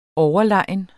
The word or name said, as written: overlegen